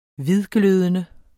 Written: hvidglødende

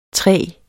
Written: træg

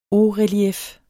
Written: hautrelief

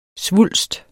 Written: svulst

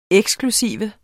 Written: eksklusiv

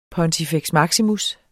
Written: pontifex

